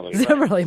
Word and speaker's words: Zimmerli: And I said that wrong. It's Zimmerli, right? – Zimmerli.